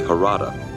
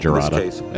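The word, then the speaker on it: Jarada: I pronounced it so wrong. – Idiosyncratic attitude toward protocol. – How would you have done it? – A precise greeting. – I said Gerada as I was reading.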